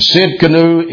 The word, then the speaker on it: Tsidkenu: And Sid Canu is spelled T-S-I-D-K-E-N-U. So the T is silent. Jehovah Sid Canu.